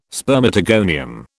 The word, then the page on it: spermatogonium: British English